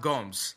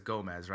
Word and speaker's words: Gomes: So, yeah, it's pronounced Gomes, right? Like, that's how it's pronounced. But, you know, we try, like, when I see it, I automatically says Gomez, right? Because that's just like the Spanish style.